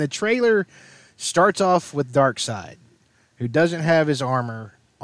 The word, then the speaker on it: Darkseid: Axis is spelled UXAS, just like Darkseid, which looks like it should be Darkseid and screws up every comic noob until somebody explains to him, no, that's Darkseid.